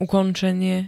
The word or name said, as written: ukončenie